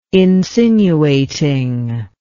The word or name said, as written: insinuating